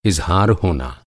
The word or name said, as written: इज़हार